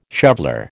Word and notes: shoveller: American English

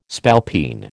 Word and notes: spalpeen: American English

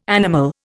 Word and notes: animal: Indian

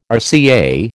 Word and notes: RCA: American English

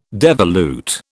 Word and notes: devolute: British English

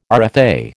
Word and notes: RFA: American English

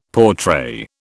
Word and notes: portray: British English

